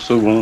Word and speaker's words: Saulgond: Oui, ça se prononce second, mais c'est S-A-U-L-G-O-N-D. – S-A-U?